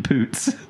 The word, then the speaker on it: Poots: Vivarium stars Jesse Eisenberg and Imogen Poon. – Imogen Poots.